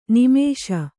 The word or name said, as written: ನೈಮಿಷ